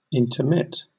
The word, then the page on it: intermit: British English